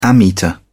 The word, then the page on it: ammeter: British English